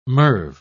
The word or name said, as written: merv